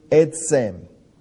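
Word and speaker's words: etz (עץ): And that is the word that is pronounced etz. And the word etz means tree.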